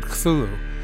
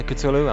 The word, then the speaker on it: Cthulhu: This is a word that everybody disagrees on the pronunciation of. – Sure. – I've always said Cthulhu. Is that close? – I would say Cthulhu, I mean, yeah.